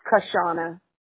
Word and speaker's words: Kashawna: So it's spelled, the W is kind of silent. That's why people kind of mess it up. Call me Kushana all the time, but it's Sean, really, and it's just Kushana, and it's spelled Kashawna.